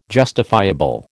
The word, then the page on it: justifiable: American English